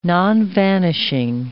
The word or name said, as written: nonvanishing